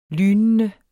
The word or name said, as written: lynende